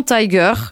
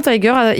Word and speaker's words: Taeger: Je dirais que ça se prononce Vincent Tiger, mais écrit T-A-E-G-E-R. Tiger.